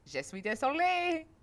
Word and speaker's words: je suis désolé: He's like, je suis désolé.